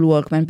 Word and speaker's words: Walkman: Ma sì, poi devo dire che anche lì io sono sempre dell'idea che bisogna sai qual era la critica sul Walkman? Perché lo pronunciavo con la L. Vabbè, però, ma perché in italiano lo diciamo così?